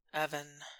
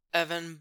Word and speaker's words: Evan: Evan. Evan, Evan, Evan. Four letters. Two syllables. No plosives. Just vowels separated by a soft fricative.